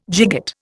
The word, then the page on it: gigot: Indian